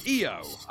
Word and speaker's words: EO: EO, which I believe is still pronounced EO in its native Polish, but is spelt IO in Polish.